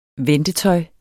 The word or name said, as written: ventetøj